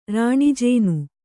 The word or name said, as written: ರಾಣಿಜೇನು